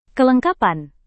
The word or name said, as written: kelengkapan